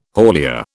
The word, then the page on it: haulier: British English